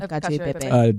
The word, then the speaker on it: cacio e pepe: What is the translation, the literal translation of cacio e pepe? – Cheese and pepper, I think.